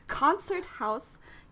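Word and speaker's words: Konzerthaus: I'm terrible German pronunciation. Okay. Concerthaus Camera Orchestra, Berlin Symphony Orchestra. – What's wrong with that? That was great.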